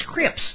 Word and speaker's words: Cripps: My maiden name was Crips, C-R-I-P-P-S. And so it got pronounced Crips a lot of times.